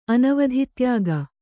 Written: ಅನವಧಿತ್ಯಾಗ

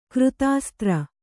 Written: ಕೃತಾಸ್ತ್ರ

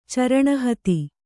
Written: ಚರಣಹೇತಿ

